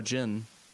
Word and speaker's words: Jin: So, apparently his name is now Jin. – So it's Jin, and like, yeah. So in Japanese it's written as Jin, like, because there's no NG noise in Japanese.